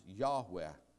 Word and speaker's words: Yahweh: Probably pronounced Yahweh.